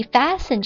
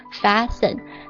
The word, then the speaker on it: fasten: fasten 这个单词你注意哦它这个 T 是不发音的 fasten